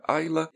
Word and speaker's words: Islay: um turfado da região da ilha de de Islay, que se pronuncia Ayla,